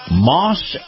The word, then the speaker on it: Maus: In this portion of the program, our conversation with Chris Markowski, the Watchdog on Wall Street, is brought to you by Moss Acura. Moss is spelled M-A-U-S.